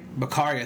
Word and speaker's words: Bakare: And Ariane Bakare? – Bakari? – Bakari, I think you're right, yeah.